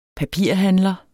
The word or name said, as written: papirhandler